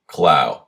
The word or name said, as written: clough